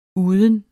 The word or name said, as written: uden